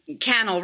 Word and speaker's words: Cannell: Well, that's where things get really heavy for sure, because we have this really great YouTuber expert named Sean Cannell, rhymes with YouTube channel, he likes to say, and he's kind of like a YouTuber expert.